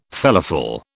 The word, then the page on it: felafel: British English